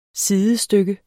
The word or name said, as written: SIDESTYKKER